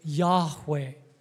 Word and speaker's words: Yahweh: And most likely the pronunciation is Yahweh.